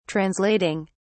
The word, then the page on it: translating: British English